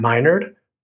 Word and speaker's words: Minard: My name is Dr. Matt Minard, pronounced my nerd, and I am honored to be your host.